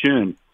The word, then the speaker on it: schön: the word in German for beautiful is S-C-H-O-N, which is Sean. If you put the umlauts over it, it sounds more like schön. So if I said, Sie sind sehr schön, that means you are very beautiful.